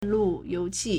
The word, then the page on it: 路由器: lùyóuqì